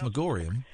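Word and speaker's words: Magorium: Mr. Megorium? – Oh, definitely. – I don't think it's pronounced Megorium. – Yes, it is.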